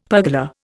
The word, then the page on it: pergola: Indian